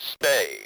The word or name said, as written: stay